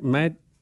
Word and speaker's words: Matvich: M-A-T-V-I-C-H? Is that how you say it? Madvich? – Madvich? You had it right when you said it.